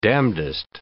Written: damnedest

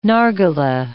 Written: nargileh

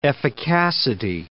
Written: efficacity

